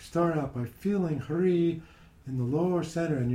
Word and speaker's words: Hrih: Now in Lama Govinda's teaching, the seed syllable for Amitabha is Hri, H-R-I-H.